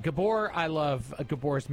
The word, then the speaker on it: Gabor: Gaber, it's actually pronounced Gaber in Tampa. – No, Tampa. It's Gabor. – Is it Gabor? – Yeah, like he says Gabor. – Like Zsa Zsa Gabor.